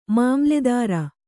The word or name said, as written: ಮಾಮಲೇದಾರ